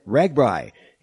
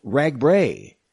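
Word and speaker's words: RAGBRAI: Well, just about everyone around these parts pronounces it Ragbri, including the Des Moines Register's own staff. But the man who helped found the race decades ago, John Karras, said he thought it was supposed to be pronounced Ragbray. So the bottom line is, however you pronounce it, it's okay.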